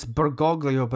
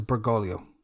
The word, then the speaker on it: Bergoglio: It's Bergoglio, but I think the easier way to pronounce it is Bergoglio.